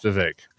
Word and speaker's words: Vivek: So it's pronounced Vivek.